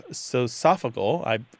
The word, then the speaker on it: sociofugal: It looks like sociopetal, socio plus P-E-T-A-L. And these are spaces that are designed to bring people together. You also have sosophagal, looks like sociofugal, so it's socio plus F-U-G-A-L, and these are spaces that are designed to minimize contact between people.